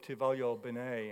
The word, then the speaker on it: ti voglio bene: The other is pronounced, I believe, ti voglio bene, and it means I want good for you.